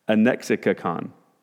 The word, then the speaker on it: anexikakon: It's a triple compound word, and so you don't see this very much, but here's the way you pronounce it for those of you who enjoy this kind of stuff. It's anexikakon. And it's ana in the midst of, echo to have or to hold, and kakos, evil or bad.